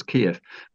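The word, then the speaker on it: Kyiv: I think the recommendation that the BBC makes now, I think, is probably Kiev, as if it was spelt sort of K-E-E-I-F, with that I'd put a hyphen between the double E and the I, so two syllables Kiev.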